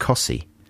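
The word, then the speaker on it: Costessey: There's another place very near Wymondham that's spelt Costessi. – and it's pronounced Cossy.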